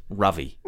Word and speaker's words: Ravi: It's called Robbie Patel's Pursuit of Happiness. And it's great. – It's for Robbie. Robbie's... What is it called? – I believe his name is pronounced Ravi.